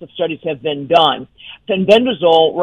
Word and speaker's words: fenbendazole: I don't know if I'm saying that right. Fenbendazole.